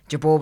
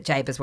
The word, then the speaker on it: Jabour: And the celebrant was an old friend of my dad's, and he knew Jabour was the proper pronunciation, but Jabour's what we used. And I told him to use Jabour because, you know, that's the name I grew up with.